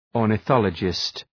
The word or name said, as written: ornithologist